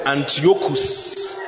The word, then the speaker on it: Antiochus: I didn't say Antiochus. I said Antiochus. A-N-T-I-O-C-H-U-S. Antiochus Epiphany. Epiphany is spelled E-P-I-P-H-A-N-Y.